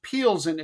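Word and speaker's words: Plzeň: Yeah, that's an L, not an I, believe it or not. They need to buy a vowel. P-L-Z-E-N. – Pilsen. – Yeah, it's actually pronounced Pilsen, it turned out.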